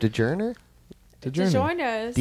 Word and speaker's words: DiGiorno: DiGiorno's. – D-I-G-I-R-N-O, right? – DiGiorno.